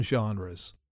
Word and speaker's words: genre: The word genre is a bit strange because the pronunciation sounds to most English people like a French word, genre. It's written G-E-N-R-E. And it's unusual in English to pronounce a G in that way.